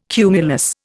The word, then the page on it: cumulus: Indian